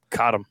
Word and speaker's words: Cottom: Cottom Jr. Did I say Cottom or Cottom? – Cottom. – Cottom. I said it right. And it's not Cotton.